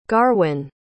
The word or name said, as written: garwyn